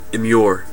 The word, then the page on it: immure: American English